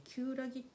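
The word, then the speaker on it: 厳木: 読み方も漢字もあの難しいんですよね。あの厳格の厳っていうんですかね厳しい「木」って書いて「きゅうらぎ」って読む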